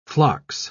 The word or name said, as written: phlox